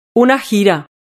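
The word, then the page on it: una gira: Spanish (Spain)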